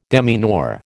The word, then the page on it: demeanour: American English